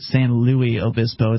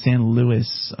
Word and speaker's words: San Luis Obispo: I'm being told that you don't actually pronounce it San Luis Obispo. It's San Luis, apparently. I don't know. I thought Louis was some different Spanish way to say it.